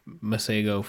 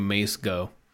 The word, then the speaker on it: Masego: I like That's how you pronounce his name? – Yeah. – I apologize, Masego, for calling you Mace Go.